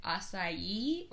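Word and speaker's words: açaí: And we are also aware that the proper pronunciation of this word is like acai or something along those lines. However, we have always refused to put that many syllables into that word.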